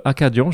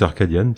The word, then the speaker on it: Arcadian: le groupe Acadian. Je ne sais pas si ou Arcadian, je ne sais pas comment faut le prononcer. D'ailleurs, je sais pas si vous connaissez. Pense que c'est Arcadian, personne.